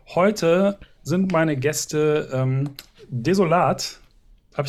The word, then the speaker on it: Desolat: tatsächlich häufig äh, als desolate äh, doch angesprochen oder ausgesprochen worden. Warum, äh, weiß ich nicht, aber es passiert doch häufiger als die deutsche Ausdrucksweise.